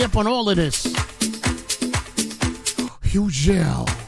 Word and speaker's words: Hugel: Hugel. Some people call him Hugel. Hugel with the silent G. He's going to get a show right here on Hot 97. Hugel, silent G.